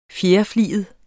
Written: fjerfliget

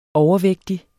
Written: overvægtig

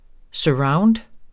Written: surround

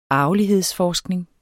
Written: ARVELIGHEDSFORSKNING

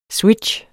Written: switch

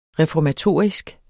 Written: reformatorisk

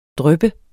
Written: DRYPPEDE

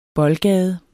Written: boldgade

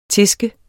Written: tiske

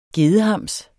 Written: gedehams

